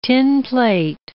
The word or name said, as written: tinplate